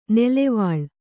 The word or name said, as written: ನುಲುವಾಲೆ